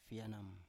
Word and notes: fienam: Biak

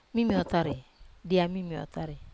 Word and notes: mimiotari: wamesa

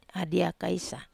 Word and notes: adia kaisa: Wamesa

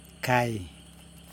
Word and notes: kaj: Yokoim

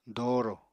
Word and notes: doorò: apatani

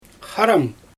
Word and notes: xaram: Xyzyl

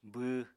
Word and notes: bww: apatani

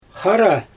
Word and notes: xara: Xyzyl

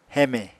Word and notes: heme: Apatani

